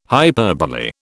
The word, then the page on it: hyperbole: British English